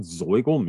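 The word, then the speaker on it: Zoigl: Does it say what Zoigle means? Like, is it a term? – I was trying to catch like a, I think it's an area. The word Zoigle is pronounced just like it looks, and it's fun to say.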